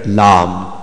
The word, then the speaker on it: Lam: This is how you describe when you're telling somebody about the letter Lam. So you don't say it La or Lu or Le.